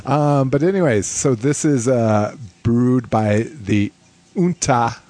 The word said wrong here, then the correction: Uinta: Is that how you pronounce that? UINTA. Yunta Brewing Company.